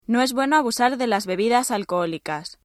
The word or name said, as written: bebida alcohólica